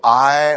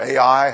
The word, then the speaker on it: Ai: They have defeated Jericho and a little city of Ai or Ai, however you want to pronounce that two-letter word there.